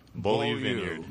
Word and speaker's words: Beaulieu: Beaulieu.